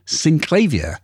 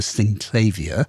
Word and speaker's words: Synclavier: Synclavier, said... – In French, I say Synclavier. That's how I pronounce it in French. But if I were to say it in English, I would probably say Synclavier.